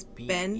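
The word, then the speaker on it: bean nighe: So it's spelled B-E-A-N, like bean, but it's Ben. – B-E-A-N. – Ben. And then Nye is N-I-G-H-E.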